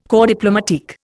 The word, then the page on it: corps diplomatique: Indian